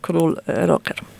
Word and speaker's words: Król Roger: W roku 2017 oglądaliśmy w Australii, w Sydney i w Melbourne operę Karola Szymanowskiego Król Roger. Ja wymawiam tak, jak Polacy mówią, król Roger. – Roger, tak właśnie.